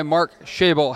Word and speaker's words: Mark Schaible: And today I'm joined by Mark Schaible.